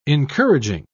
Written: encouraging

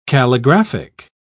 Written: calligraphic

